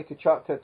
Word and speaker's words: Choctaw: To Choctaw. – Choctaw. Oh, Choctaw.